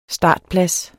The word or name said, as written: startplads